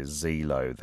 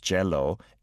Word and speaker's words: Zelo: I heard that being sung by Zelo there, pronounced Jello in Hangul because we don't have a Z or Z sound in the language.